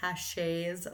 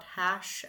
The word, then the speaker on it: Hache: Thank you to the listener who actually emailed us after part one dropped and let us know that Rory Hache's last name is pronounced Hache and not Hash as I originally pronounced it at the beginning of part one.